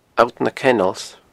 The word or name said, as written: Allt na Kennels